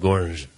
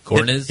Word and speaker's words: Gornicz: How would you pronounce this? G-O-R-N-I-C-Z? – Yeah, I'd just go with Gornich. – Is that what you'd say? – Yeah. – Gornich. – Hit the gore real hard.